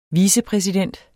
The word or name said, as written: vicepræsident